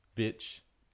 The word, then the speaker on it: Stefanovic: Karl Stefanovic? – Vich. – No. – Vich. – It's not Karl Stefanovic.